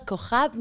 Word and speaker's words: Cojab: Adela Cojab and I.